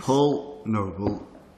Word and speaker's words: Paul Noble: Paul Noble, P-A-U-L-N-O-B-L-E.